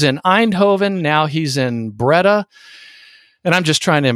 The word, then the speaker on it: Breda: I think it's Breda. – Breda, yeah.